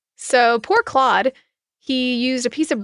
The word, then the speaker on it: Claude: Like Claude is how it's pronounced.